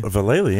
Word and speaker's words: Vallely: Been listening to you pronounce it Valley, but do you remember Mike Vallely? – Vallely, yeah.